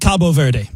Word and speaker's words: Cabo Verde: I like Cabo Verde. – Cabo Verde. Jason Stewart, our executive producer. How do you say it? – How said it.